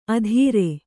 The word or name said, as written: ಅದಿರೆ